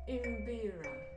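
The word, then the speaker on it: mbira: So they're pronounced as imbira. Imbira.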